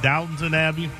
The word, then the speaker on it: Downton Abbey: Downton Abbey.